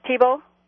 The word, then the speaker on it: Thibault: Thibault? Yeah. – Okay. – It's spelled like Theobald, but yeah.